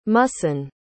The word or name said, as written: muhsin